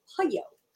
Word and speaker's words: pollo: And I'm just going to take 1 second to say you totally butchered that word, because the Spanish word is pollo. Pollo.